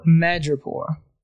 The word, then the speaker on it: Madripoor: Madripoor.